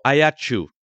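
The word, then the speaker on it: Aiacciu: Ajaccio, or Ayacu, as it's known, since we're giving correct pronunciations in the course language.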